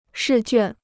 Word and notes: 试卷: shì juàn

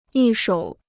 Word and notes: 一手: yī shǒu

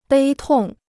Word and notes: 悲痛: bēi tòng

